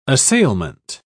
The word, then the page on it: assailment: British English